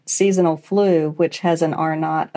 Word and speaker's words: R0: It's written as R0 and pronounced R0.